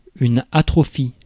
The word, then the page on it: atrophie: [at.ʁɔ.fi]; France